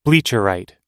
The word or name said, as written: bleacherite